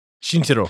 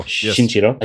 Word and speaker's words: Shinichiro: I can't say his first name. – Shinichiro. – Shinichiro.